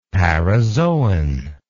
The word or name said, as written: parazoan